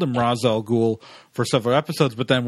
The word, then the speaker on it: Ra's al Ghul: For the listeners and viewers who aren't aware, Ra's al Ghul, and that's how you're supposed to say his name, often has his name mispronounced in various media as Ra's al Ghul.